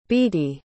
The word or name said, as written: beattie